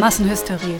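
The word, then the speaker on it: Massenhysterie: The song's called Massenhysteria. Did I pronounce that correctly? – Massenhysteria.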